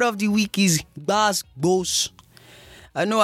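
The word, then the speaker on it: gbas gbos: It's spelled G-B-A-S space G-B-O-S. Basbos. So there's emphasis on the GB that you have to like sound it out. B. You know what I mean?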